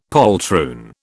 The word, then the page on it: poltroon: British English